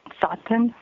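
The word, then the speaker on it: Tsaatan: These are called the Tsatan, spelled with a T, T-S-A-A-T-A-N.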